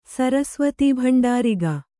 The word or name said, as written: ಸರಸ್ವತೀಭಂಡಾರಿಗ